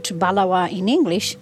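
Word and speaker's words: Baluwaa: Balawa is respect.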